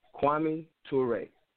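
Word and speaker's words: Kwame Ture: So Stokely Carmichael, born Stokely Carmichael, he changed his name to Kwame, I believe it's pronounced Toure. Kwame Toure.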